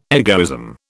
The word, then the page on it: egoism: British English